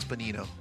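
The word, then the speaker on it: Benigno: I believe the G is silent. I believe it's Benito. – Benito, G is silent. It's B-E-N-I-G-N-O, and we carry the brand, but I just can't pronounce it because it's a G.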